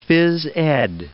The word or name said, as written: phys ed